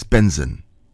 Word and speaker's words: BNXN: I had to look up how to say his name as well, because it's BNXN. But it's Benson.